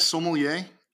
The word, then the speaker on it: sommelier: What is the correct pronunciation? – Yes, sommelier.